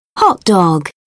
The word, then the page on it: hotdog: British English